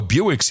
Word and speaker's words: Buick: Hey, man, that's not how you... No, Bewick is B-U-I-C-K. We all know Bewick's here.